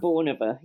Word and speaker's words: Bornova: One thing that I really enjoyed was you were searching for a farmhouse outside Bulgorka. Is that how you pronounce it? Bulgorka? – Bornova, yes. Outside of Bornova, yes. – Ah, okay. Completely wrong.